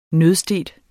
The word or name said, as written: nødstedt